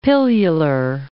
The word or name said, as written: pilular